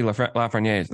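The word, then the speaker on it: Lafrenière: So it's going to be Lafreniere. That's how you say it, by the way.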